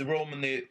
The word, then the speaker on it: Romney: Jag har alltid uttalat om Rodney. – Ja, Rodney. – Det är alltså ett M i namnet. Mitt Romney, vad han kan prestera liksom, vad han kan göra.